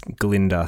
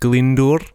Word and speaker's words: Glyndŵr: My middle name is Glinda. So, I once met this Welsh lady and she said that I'm pronouncing my own name incredibly wrong. – Okay. – My middle name is actually pronounced Glyndŵr.